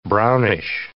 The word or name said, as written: brownish